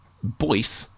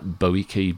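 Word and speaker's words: Boece: Miles Peterson, at MilesPeterson3 on Twitter, said that Hector Boyce, as it should be, rhymes with voice. – Hope what were helps. We saying? – I was saying I was really strong. I was like Boike, Boica, Boosa.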